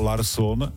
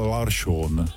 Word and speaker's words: Larsson: Robert Záruba přestal počešťovat a číst je tvrdě, ale začal je číst švédsky, takže místo Larsson říkal Larsson.